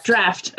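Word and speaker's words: draught: The draft? Drought. – Mm-hmm. – Draft. – Okay, thanks.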